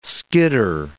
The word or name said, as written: skidder